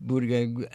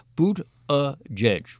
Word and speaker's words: Buttigieg: It's worth noting that two polls have now shown Pete Buttigieg as a front runner in Iowa. It's time we gave him respect of correct pronunciation, and I'm sure this is to me, Buttigieg.